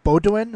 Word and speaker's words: Bowdoin: So you started out, you went to like we said, Division III at, Bowdoin.